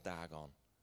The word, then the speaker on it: Dagon: Then they carried the ark into Dagon's temple and set it before Dagon. Am I pronouncing that right? That'll do.